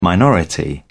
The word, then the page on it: minority: British English